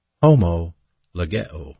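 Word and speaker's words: homologeo (ὁμολογέω): But the verb confess is a compound verb, pronounced in the Greek New Testament as homologeo. Homologeo.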